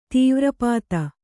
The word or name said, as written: ತೀವ್ರಪಾತ